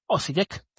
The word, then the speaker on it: Osijek: ¿cómo se dice? Ojisek de Croacia. Osijek de Croacia ante el Dinamo Zagreb, el equipo que decíamos que es el único equipo top en esa liga.